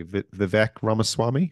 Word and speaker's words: Vivek Ramaswamy: Vivek Ramaswamy, I think his name is.